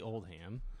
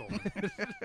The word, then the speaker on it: Oldman: Is it Oldman or is it, how do you say it? – I say Oldham. – Oldham? – It's funny. – I thought it was Okay. – It's Oldman.